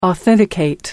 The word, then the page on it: authenticate: American English